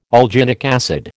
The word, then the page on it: alginic acid: American English